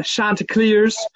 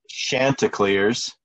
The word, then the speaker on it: Chanticleers: And you went with Chanticleers, right? And not, so if you look at the pronunciations I saw on ESPN, it has like a S-H-A-N.